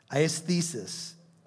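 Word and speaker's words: aisthesis: It's A-I-S-T-H-E-S-I-S. A or A-E-thesis. Aesthesis.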